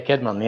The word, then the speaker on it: Caedmon: Is it Caedmon? – Yeah, Caedmon, yeah.